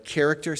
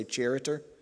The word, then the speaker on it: character: Like character, ch, character, the word character, ch, you don't say charitor. Okay, it's character, C-H, hardened, even in English. So you pronounce the C-H as a K.